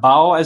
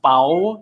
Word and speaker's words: Bao: The creator of Turning Red also created the now infamous Pow, or Bao, as some people call it. It's actually pronounced Pow, so please learn how to pronounce it.